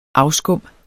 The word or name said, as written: afskum